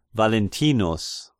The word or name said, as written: Valentinus